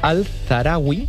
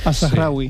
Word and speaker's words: Al-Zahrawi: Pues mira, el primero que he traído es Al-Zahrawi. Ya me dirá si lo he pronunciado bien o mal. – Al-Zahrawi.